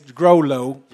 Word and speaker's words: Groleau: Before I, I interviewed you just when you came and you uh, said the correct pronunciation is Grolo.